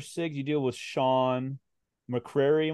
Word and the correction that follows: McCray: am I saying that right? – McCray. – McCray, excuse me, from Newark, New Jersey.